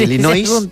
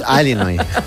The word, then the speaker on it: Illinois: Es un estado de Estados Unidos que se pronuncia así. Es sí, Illinois, pero es Illinois.